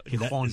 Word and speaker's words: Gijón: Gijón. Sí, Gijón. Lo pronuncian la J de otra manera.